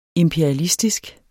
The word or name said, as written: imperialistiske